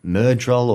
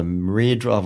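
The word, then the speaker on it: Myrddraal: And then you sort of have, you know, Rand, like, seeing the Myrdral or the Myrdral.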